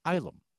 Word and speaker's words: Ylum: So this is the first time me, as a new reader, saw Nexus world Ilum, which, for if you've not read Nexus, it's spelled Y-L-U-M, and it's sort of short for asylum, because the Ilum, the world, is an asylum for all sorts of people from all over the galaxy, mm-hmm.